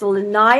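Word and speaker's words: lanai (לענה): We see that it was pronounced lanai in Hebrew. Wormwood is pronounced lanai.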